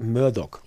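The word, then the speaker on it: Murdaugh: Sagt man Murdoch oder Murdoch? Alle sagen Doc, aber ich habe das Gefühl, es müsste eigentlich. – Alle sagen, dass es Murdoch ausgesprochen wird, obwohl es sich wirklich eigentlich nicht so schreibt.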